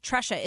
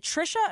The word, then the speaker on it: Tresha: Sergeant Parks, I thought you said Teresa Parks, and then I looked online and it says T-R-E-S-H-A, like Tresha. Is it Trisha or Tresha? – It's pronounced Tresha.